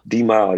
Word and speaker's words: D Mal: Is that how you pronounce it, D Mal? – D Mal, yeah.